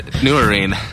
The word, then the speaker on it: Nurin: As, in Kara, as in Sarah, as in Nurin, as in tambourine, so I didn't say it right. Nurin. Kara Nurin.